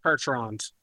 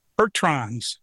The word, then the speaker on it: Percherons: Percherons. – Percherons. Did I say that right? – Yep.